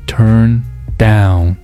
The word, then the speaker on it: turn down: 美语的发音规律是会把动词短语的重读放在后面，所以说要把它读成 turn down turn down，这个重点还在这个 down 上。